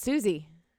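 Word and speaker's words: Sosie: Sozy? I thought it was Sozy. – It's Susie.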